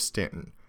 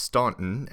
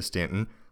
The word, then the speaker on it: Staunton: From Harrisonburg, we'll get on Interstate 81 and head to the city of Staunton. Commonly mispronounced Staunton, and if you do pronounce it that way, they will immediately know that you're an outsider.